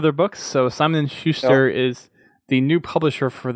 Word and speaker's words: Schuster: Next thing I want to cover would be let's just do the Simon and Suture. How do you say that? Schuster. Schuster, thank you.